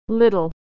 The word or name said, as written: little